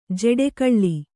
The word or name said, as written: ಜಡೆಗೊಳ್